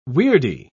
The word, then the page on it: weirdy: British English